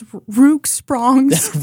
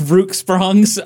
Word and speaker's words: Rock Springs: that's not how you pronounce it. – Which one? Which one? – It's Rooksprongs. – Rooksprongs? Oh, shit. I really should have consulted with the locals.